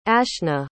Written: ashna

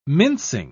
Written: mincing

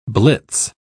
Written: blitz

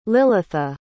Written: lilitha

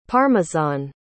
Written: parmesan

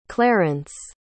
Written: clarance

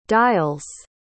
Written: dial's

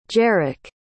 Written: jerick

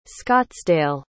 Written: scottsdale

